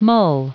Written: mull

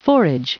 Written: forage

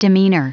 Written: demeanor

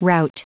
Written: rout

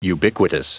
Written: ubiquitous

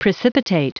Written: precipitate

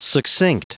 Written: succinct

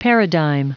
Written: paradigm

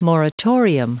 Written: moratorium

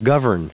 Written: govern

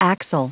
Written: axle

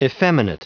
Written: effeminate

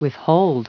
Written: withhold

